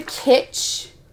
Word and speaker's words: Kitsch: You know, mine is the Kitsch. Is that how you say it? – Yeah, Kitsch.